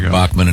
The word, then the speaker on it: Bachman: Yeah, Randy, he pronounces it Bachman, but I guess they're Bachman in the United States, is a terrific guy and a great...